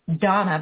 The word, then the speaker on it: Dana: Or her email is Donna, but it's Dana, D-A-N-A. It's just because it's Czech, it's pronounced differently. So it's D-A-N-A communicates.